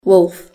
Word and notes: wolf: British English

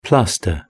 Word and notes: plaster: British English

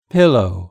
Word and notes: pillow: British English

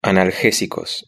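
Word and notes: analgesicos: Spanish (Spain)

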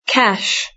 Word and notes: cash: British English

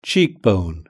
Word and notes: cheekbone: British English

